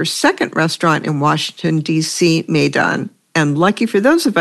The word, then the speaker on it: Maidan: Maidan, pronounced Maidan, Maidan, or Midan, doesn't carry a passport, writes Rose Previtt.